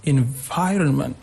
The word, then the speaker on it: environment: You can take the word like environment. You see, like on the second syllable, we have this stress, environment.